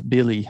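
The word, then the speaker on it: Willy: And his brother, Billy Hernan Gomez, Billy, I think is how you say it. Even though it's a W, it doesn't make any fucking sense Spanish.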